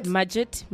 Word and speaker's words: Mudgett: Mudgett.